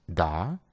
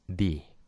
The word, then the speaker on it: the: Por si no lo sabías, la palabra da tiene dos pronunciaciones. La primera es da, cuando la siguiente palabra empieza por un sonido consonante, como la M, la T, la G, etc. Y la segunda es di, cuando su siguiente palabra empieza por un sonido vocal, como la E, la I, la O, la U, etc.